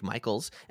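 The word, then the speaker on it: Michels: Yeah, you can find me personally on social media at The Jake Michaels. And my last name, Michaels, is spelled without an A. So it's The, T-H-E-J-A-K-E-M-I-C-H-E-L-S.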